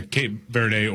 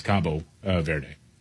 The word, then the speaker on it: Cabo Verde: Yeah, Cape Verde, or in Portuguese, it's Cabo Verde.